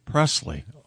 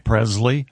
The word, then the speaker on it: Presley: Why are you mispronouncing Presley? – What did I say? – Presley? – It's a hard Z.